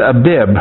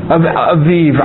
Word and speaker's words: Abib: Now, let me, I don't know where it is, the word Abib. I thought it was, it's what? Aviv, I'm sorry. A-B-I-B, the B is pronounced like a V, yeah. I don't do Hebrew pronunciations too good. A-B-I-B, okay?